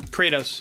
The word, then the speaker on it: Kratos: Kratos.